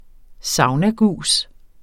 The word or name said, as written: saunagus